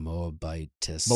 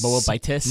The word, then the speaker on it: Moabitess: A Moabitess. – Moabitess.